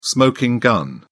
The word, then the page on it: smoking gun: British English